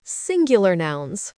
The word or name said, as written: singular nouns